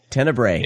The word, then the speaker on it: Tenebrae: I finally watched, and I'm probably going to, tell me if I'm pronouncing this wrong or not, but Tenebrae? – Tenebrae? – Tenebrae? – No. You can Tenebrae? – Tenebrae. – Yeah, it's okay. Tenebrae. – There you go.